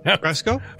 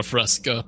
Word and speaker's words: fresco: So, actually is a fresco. Not a fresca.